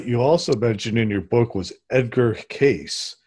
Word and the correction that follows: Cayce: Casey. So I always mispronounce things.